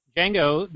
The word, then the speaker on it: Django: That would be Django, the D is Silent, Unchained.